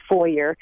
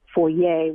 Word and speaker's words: foyer: The best pronunciation supposedly is foyer and not foyer, which sounds more affected supposedly.